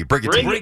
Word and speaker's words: Brigantine: Brigantine? There you go. – No, it's a hard G. – Brigantine.